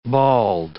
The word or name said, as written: bald